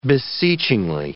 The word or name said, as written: beseechingly